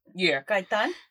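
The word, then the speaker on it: Gaitán: It's Carolina Gatan. I hope if I pronounced it right. Yes. – Carolina Gaitan.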